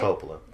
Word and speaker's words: Coppola: Coppola, I always say it wrong. Coppola, right? – Coppola.